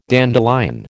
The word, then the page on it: dandelion: American English